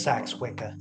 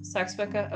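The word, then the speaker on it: Seax-Wica: However, in 1973, they ceased practicing Gardnerian Wicca and formed a new branch, which, again, pronunciation is a little bit different. – Sax Wicca. – Sax Wicca, okay. It's just spelled weird, so I wasn't quite sure.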